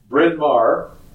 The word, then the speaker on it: Bryn Mawr: Bryn Mawr, Pennsylvania. I guess I pronounced that correct.